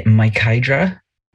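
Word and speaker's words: Mai Kadra: Yeah, Mikaidra. – How do you spell that? – M-A-I space K-A-D-R-A. – D-R-A?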